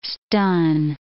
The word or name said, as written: stun